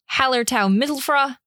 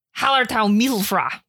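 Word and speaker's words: Hallertau Mittelfrüh: So its lineage includes Tettnanger, Hallertau Mittelfra, Brewer's Gold. – You said that wrong. – Hallertau Mittelfra. – There we go.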